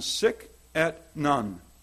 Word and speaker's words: Sic et Non: The book he wrote is called in the Latin, Sic et Non.